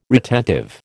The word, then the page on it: retentive: American English